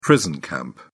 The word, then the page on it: prison camp: English; British English